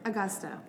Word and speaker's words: Agosta: Am I saying that right? – Agosta. Augusta. – So okay. Close.